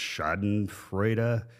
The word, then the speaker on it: Schadenfreude: Although I have been a little, I don't speak German, but there's the word, I think it's pronounced Schadenfreude.